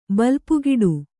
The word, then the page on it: ಬಲ್ಪುಗೆಡು: balpugeḍu